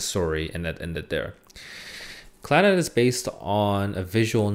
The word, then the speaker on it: Clannad: I still can't properly pronounce this show. It's CLANNAD, Clannad.